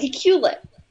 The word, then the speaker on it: Iqaluit: There's a way to pronounce this, but looking at the word, I can't figure out how to pronounce it, but I know that we learned in school. No, it's Iqaluit. But that's not how it's spelled written on paper.